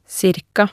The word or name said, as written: ca